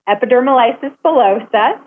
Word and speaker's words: epidermolysis bullosa: And first of all, EB, otherwise known as epidermolysis bullosa, so that's the pronunciation in case you're wondering,